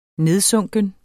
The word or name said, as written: nedsunken